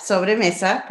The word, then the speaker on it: Sobremesa: Did I say that right? Yep, Sobre Mesa. Perfect.